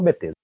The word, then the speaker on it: Bethesda: Ah, também, no dia da gravação desse episódio, olha só, além do, do anúncio do novo Pokémon, a Bethesda, a Bethesda, não sei a pronúncia correta, fala Bethesda, é, soltou no Twitter um teaser que um remete gif.